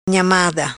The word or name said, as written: Nhamada